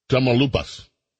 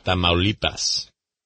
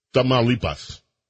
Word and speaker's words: Tamaulipas: Tamaulipas. I think that's how you pronounce the province. – Tamaulipas. – I had it almost right. – Yep. – Say it one more time, please. – Tamaulipas. – Tamaulipas.